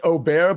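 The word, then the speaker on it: Åberg: Yeah, it's actually technically pronounced Aubert, but it's spelled A-B-E-R-G.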